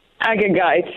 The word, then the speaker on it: Agagite: Agagite.